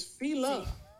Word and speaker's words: Fila: I'm going to let you hear how it's actually pronounced. Hold on. It's Fila. – It's Fila.